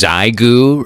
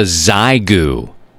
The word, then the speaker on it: Xiegu: Hey, have you seen this Zygoo radio, this transceiver? It's a 5-watt multi-mode transceiver. You can only get it here in the States at MFJ. It's called a Zygoo. It's spelled X-I-E-G-U.